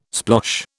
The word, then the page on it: splosh: British English